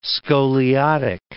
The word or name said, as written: scoliotic